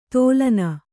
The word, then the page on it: ತೋಲನ: tōlana; Kannada